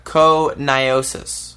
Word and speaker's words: pneumoconiosis: Pneumoconiosis. – Oh, thank you for pronouncing it. Pneumoconiosis.